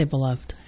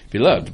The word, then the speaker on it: Beloved: And Beloved, or Beloved, how do you pronounce that? – I say Beloved. – Beloved by Toni Morrison on February 21st.